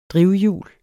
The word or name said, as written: drivhjul